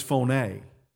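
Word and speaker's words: phone (φωνή): That word voice is very unique in the Greek. It's where we get the word phone. It's phone. Phone. It's spelled exactly the way. P-H-O, with dot across it, omega, N-E, line across it.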